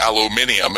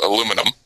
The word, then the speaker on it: aluminium: because over in England they pronounce it aluminium instead of aluminum.